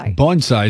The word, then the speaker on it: bonsai: How do you say that? – Bonsai. – Bonsai is the correct pronunciation.